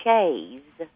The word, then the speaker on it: chaise: Yeah, because all my life I always just said Chaise, and we about all of us do, but the correct pronunciation, according to the dictionary, and again, it is a French word meaning chair, chaise, like S-H, long A-Z.